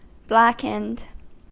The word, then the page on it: blackened: American English